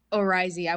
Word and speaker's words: oryzae: How do you pronounce it again? – Oryzae. I was the same way.